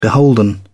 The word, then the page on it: beholden: British English